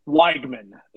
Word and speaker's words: Weigman: Weigman is how you say it. – Oh, Weigman. – That's so much worse than Weigman. God. – No, the E and I in what I can only assume is German or Czech or whatever. You say it like a Y right there.